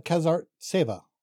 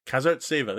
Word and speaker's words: Kazartseva: I believe it's Ekaterina Kazartseva. – Kazartseva. There you go. Actually pronouncing all of the consonants this time.